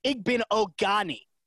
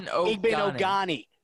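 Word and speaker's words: Igbinoghene: So that pronunciation, just to be precise, is Igben Ogani. That is Noah Igben Ogani.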